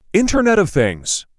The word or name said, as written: Internet of Things